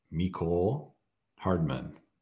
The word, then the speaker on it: Mecole Hardman: And my choice for his squad is Mecole Hardman. Not sure how that's how you pronounce it. – I think he nailed it.